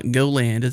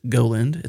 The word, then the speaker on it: GoLand: And today, from what I understand, we're talking about GoLand, which is not GoLand. It's GoLand.